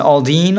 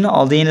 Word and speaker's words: Aldean: Jason Aldean. Aldean, is that the way you pronounce it? – Yes.